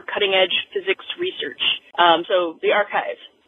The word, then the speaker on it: arXiv: I was greatly amused to hear the description of the physics preprint collection called ARXIV. It's actually pronounced archive, which is kind of cool.